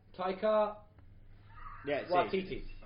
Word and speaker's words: Taika Waititi: Tahiti Wahiti? – Taika Waititi.